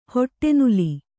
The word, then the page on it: ಹಿಟ್ಟುನೆಲ್ಲಿ: Kannada